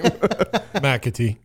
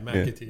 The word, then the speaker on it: McAtee: McAtee. – Oh, okay. All right. Is that, that's how you say it, right? – Yeah, McAtee.